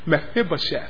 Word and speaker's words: Mephibosheth: I can't say his name. Mephibosheth. There you go.